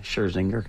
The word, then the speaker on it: Scherzinger: Yeah, Scherzinger.